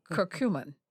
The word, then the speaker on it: curcumin: curcumin.